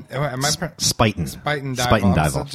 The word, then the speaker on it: Spuyten Duyvil: She asks, Allison Hamlin, the deputy director of Heritage Radio Network, Sputin Dival open. – Spuyten Dival. So the U and the Y is pronounced like an I.